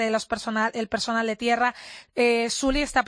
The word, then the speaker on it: Sully: Clint Eastwood, que tiene ya 86 años y yo creo que no puede estar más guapo, estrena una película titulada Sally, que tiene el que se escribe Sully. – Sully, efectivamente Sully, que